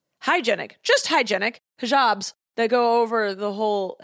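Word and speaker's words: hijab: Hijabs? – Hijabs.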